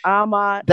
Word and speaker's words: amat: Here's the rule. If a word has two syllables, unless it's the word adhuk, that's the only exception. Unless it's the word adhuk, always accent the first syllable. If it's a two-syllable word, always accent the first syllable. I know, Jane, we were in school. We said amo, amas, amat. You know, we did. But it's really amat.